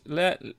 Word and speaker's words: L'Angelus: L'Angelus. From. – I don't think you pronounce the apostrophe, mate.